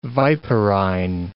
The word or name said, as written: viperine